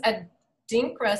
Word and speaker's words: Adinkra: So they're called Adinkra symbols. I don't know how to pronounce that word. It's A-D-I-N-K-R-A. Adinkra? – Adinkra? – Adinkra? Adinkra? Okay, so they're symbols that originated in West Africa.